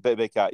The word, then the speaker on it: Bacot: But even then, you know, McCormick, I think, did more than held his own against, a guy who most likely, how do you say his last name, Bacot or something Bacot. Like that? Bacot, yeah.